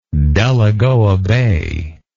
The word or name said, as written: Delagoa Bay